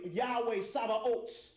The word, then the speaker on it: Yahweh Sabaoth: the Yahweh, Yahweh Sabaoth is the Hebrew pronunciation.